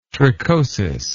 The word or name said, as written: trichosis